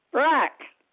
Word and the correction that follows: brap: And brap, so B-R-A-P.